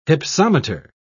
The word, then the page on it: hypsometer: British English